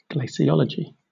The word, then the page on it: glaciology: British English